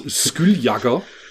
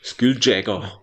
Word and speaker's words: Skulljagger: im Gegensatz zum Skull, wie spricht man das aus? Skulljagger. Skulljagger. Michael Skulljagger. Äh, ja, stimmt, Mick Jagger. Mick Jagger. Stimmt, Skulljagger.